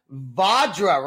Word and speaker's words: Vajra: We are with the band Vajra, right? That's how you say it. – That's it. You did it.